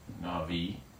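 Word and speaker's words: Navi (נביא): So a Navi, sometimes you'll spell it like that, it's a B, but it's pronounced as a V. Navi is the Hebrew word for a prophet.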